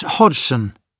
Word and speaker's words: Hodgson: It's Hodgson, Bob Hodgson. – Could you spell that? – Sure. It's H-O-D-G-S-O-N.